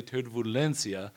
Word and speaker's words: turbulencia: And evidently the whole bit of that story, I pronounced turbulence incorrectly in Spanish. So I said turbulencia, which sounds, you know, everybody knew what I was talking about. It's evidently turbulencia. Messed up the vowel there.